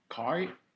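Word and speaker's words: khói: So it's not koi. It's koi. I could pronounce it again. – Yeah, kai. It's like kai In the back of your throat.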